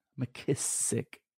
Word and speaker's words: McKissick: McKissick. – Make sure you say it like it's a kiss.